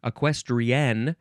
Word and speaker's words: equestrienne: The next word is equestrienne, you do have to emphasize that last syllable, equestrienne, because it is spelled E Q U E S T R I E N N E.